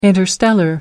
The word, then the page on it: interstellar: American English